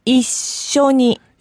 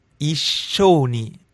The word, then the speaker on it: 一緒に: 一緒に。 – Yeah, the S is kind of held. – Yes. – 一緒に。 – Yes. – The S is held and the O is short.